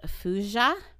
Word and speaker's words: thujone: And then wormwood itself contains something called fuja, but it's spelled T-H-U-J-O-N-E.